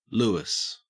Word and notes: louis: Australian English

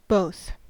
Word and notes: both: American English

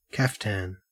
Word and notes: kaftan: Australian English

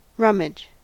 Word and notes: rummage: American English

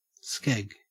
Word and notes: skeg: Australian English

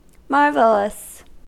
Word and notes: marvelous: American English

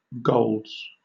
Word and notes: golds: British English

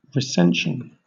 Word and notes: recension: British English

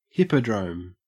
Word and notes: hippodrome: Australian English

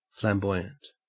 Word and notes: flamboyant: Australian English

